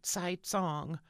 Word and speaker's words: Cai Cong: So, to start out, Kai Kong's name is actually spelled C-A-I-C-O-N-G, and it probably should sound something like Tsai Tsong, but I'll stick with Kai Kong, with your forgiveness.